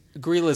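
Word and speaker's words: Grýla: Again, something that I looked up the pronunciation of. There's a whole website where you type in Icelandic words and it'll spit it back to you on how you're pronounced. So this is pronounced Grilla.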